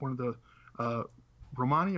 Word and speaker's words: Romani: I think it's, I've heard it pronounced both ways, but Romani is the way I hear it most commonly.